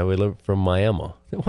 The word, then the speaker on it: Miami: And it is Miami, Oklahoma, even though it's spelled with an I at the end.